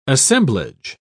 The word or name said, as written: assemblage